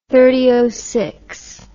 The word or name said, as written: thirty nine articles